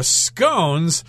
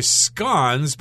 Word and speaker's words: scone: Now, scones, that is the American pronunciation, and if you talk to somebody from the UK, they're probably going to say scones, but I've heard them say scones as well, so you probably have to talk to your British friends and ask them exactly how they say it so you know how to pronounce it in conversation.